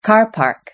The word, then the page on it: car park: British English